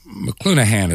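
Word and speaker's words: McLuhan: Marshall McClenahan, as I recall, is the pronunciation of his name.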